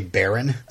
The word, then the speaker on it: Baern: Barn. – It's spelled B-A-E-R-N, but every mm-hmm. time they talk, they say Baron.